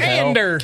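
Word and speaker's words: Andor: and Cassian Ander, if you say it like in the trailer. – What the Ander.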